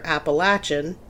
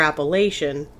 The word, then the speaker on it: Appalachian: It's pronounced two different ways depending on where you live, either Appalachian, if you're from the South, or Appalachian, if you're from kind of the Mid-Atlantic region north.